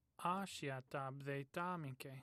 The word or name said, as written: ashiata